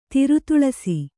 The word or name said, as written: ತಿರುತುಳಸಿ